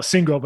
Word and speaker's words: Singal: and Jesse Signal, I believe I'm pronouncing that right. – Single, but that's okay.